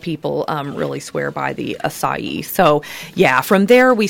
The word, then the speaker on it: acai: Acai is how you say it.